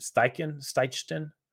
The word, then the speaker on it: Steichen: And he's also in the same offense that Shane, I'm sorry, I don't know how to pronounce his last name, Steichen. She was the Chargers. – That sounds right.